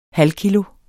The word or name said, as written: halvkilo